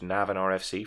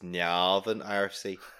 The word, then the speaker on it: Navan: He coached Navan RFC for 5 years. – You said that wrong. It's Navan RFC.